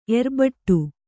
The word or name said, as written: ಏರುಬೆಟ್ಟ